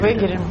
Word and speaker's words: Эгере: Сижу зимой в Эгере? Где ударили ставить? – В Эгере.